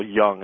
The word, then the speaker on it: Jung: And which your disconnect, it really goes back to, I believe it's Carl Jung is the pronunciation of his last name,